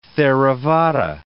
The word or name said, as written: Theravada